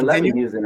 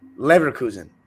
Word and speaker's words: Leverkusen: Well, it's Bayer Leverkusen, right? – Leverkusen. – Oh, Leverkusen.